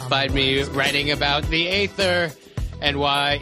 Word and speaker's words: Aether: No, they call it the Aether. They call it the Aether in Thor the Dark World, but it is spelled Aether, A-E-T-H-E-R.